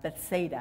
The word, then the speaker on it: Bethsaida: It's about four miles away, about a two hour trip near a town called Bethesda. Uh, Bethsaida, I pronounced it wrong.